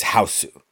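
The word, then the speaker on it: Hausu: Oftentimes, just like The Ring is referred to as Ringu when it's in Japanese, this is Houseu. You don't really pronounce the U at the end of it anyway, but...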